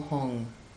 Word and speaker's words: Hung: In the center of the moon is his seed syllable Hong, like radiant silvery syllable standing upright. You just visualize H-U-M or H-U-N-G standing upright.